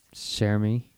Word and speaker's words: Cher Ami: I don't know how this is pronounced. Cher Ami? Ami? How do you think that's pronounced? – Cher Ami.